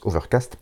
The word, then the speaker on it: OVRKST: enfin O-V-R-K-S-T, mais on va dire que ça se prononce Overcast, il manque des voyelles,